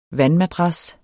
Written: vandmadras

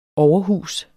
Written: overhus

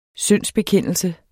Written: SYNDSBEKENDELSES

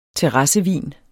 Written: terrassevin

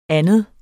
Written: anden

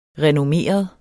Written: renommeret

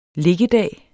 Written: liggedag

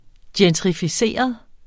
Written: gentrificeret

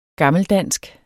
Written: gammeldansk

